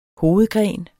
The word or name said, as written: hovedgren